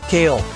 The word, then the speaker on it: Kahle: lots more with Dave Kahle. K-A-H-L-E. Well, that's really important. It's not spelled like the salad. He's got a little H in there.